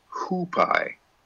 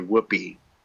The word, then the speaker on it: Whoopi: and he kind of read it phonetically as in Hoopie. What is a Hoopie? And trying to say Whoopi Goldberg,